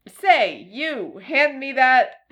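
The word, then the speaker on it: Ceiu: But it's C-E-I-U, letter U. But conveniently, it's pronounced like the words say you.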